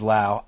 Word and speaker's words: Lowe: Yes, and I mispronounced his name. It is Lau. Good for you. – Because Nathaniel Lowe, who is not related, has it spelled the same way, is in the same organization. He's Lowe. Yeah.